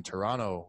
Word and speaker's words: Toronto: Because in the movie, he's like, they say Toronto, no T. And then I discovered my whole life I've been saying Toronto with no T.